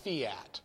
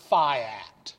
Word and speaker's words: fiat: Now, the correct pronunciation of this is fiat, but you'll hear pronounced fiat by pseudo-intellectuals.